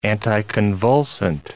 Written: anticonvulsant

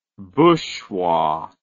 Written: booshwa

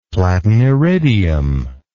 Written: platiniridium

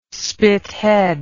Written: Spithead

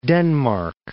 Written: Denmark

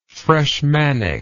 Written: freshmanic

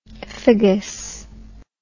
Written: phagous

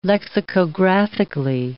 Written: lexicographically

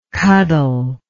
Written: cuddlesome

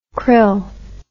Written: crill

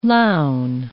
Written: lown

